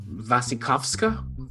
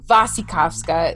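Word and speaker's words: Wasikowska: Uh, Vasikovska. – Vasikovska. It's the it's with a W, but you have to do the va.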